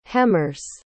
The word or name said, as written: hemmers